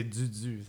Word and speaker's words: Dudu: L'album Doudou, euh, si vous cherchez, c'est Dudu. Ça s'écrit D-U-D-U, ah.